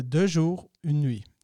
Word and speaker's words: Deux jours, une nuit: Och den hette Deux jours, une nuit. Två dagar, en natt. Korrekt. Får mitt uttal godkänt? Absolut.